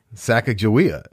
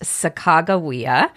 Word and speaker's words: Sacagawea: It's Sacagawea, which is the wrong way to pronounce it, but that's, hey, I went to school in the 1960s and 70s, and back then, they taught us it was Sacagawea. – Right. And now scholars believe that it's pronounced Sacagawea, and the baby was her son, Jean-Baptiste Charbonneau.